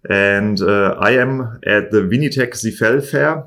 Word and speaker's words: Vinitech Sifel: It is spelled V-I-N-I-T-E-C-H-S-I-F-E-L. Vinitech Sifel, or how you pronounce it in Francais, Vinitech Sifel.